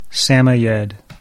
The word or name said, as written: Samoyed